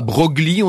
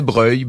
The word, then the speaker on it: Broglie: On disait pas Broglie, on disait Breuil.